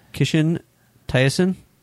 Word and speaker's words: Kishin Taisen: It's, you can look it up as Kishin Taishin. It's spelled KISHIN TAISEN.